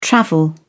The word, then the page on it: travel: British English